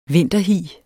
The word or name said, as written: vinterhi